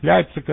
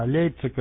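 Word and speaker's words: Leipzig: Лейпциг — это как бы получается по-русски вроде так оно звучит, как нас в школе учили, а Лейпциг — это вроде как по-немецки. По-заксонски это вообще Лейпцищ будет.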